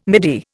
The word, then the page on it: midi: Indian